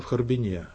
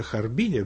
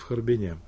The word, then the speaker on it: Харбин: Ну и вот эта женщина, автор этой статьи, которая прислала о русско-китайском пиджине статью, она изучала в Харбине, с таким ударением именно. Вообще Харбине принято, но все харбинцы говорят в Харбине.